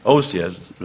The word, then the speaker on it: Osea: Let's read in Hosea. No, Hosea? Yeah, Hosea. Hosea. Anyway. Osea is what Brother Freestone pronounced. That's the way I'll say it.